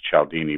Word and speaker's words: Cialdini: His last name is spelled Cialdini, pronounced Cialdini.